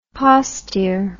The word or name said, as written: postier